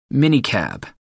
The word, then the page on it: minicab: American English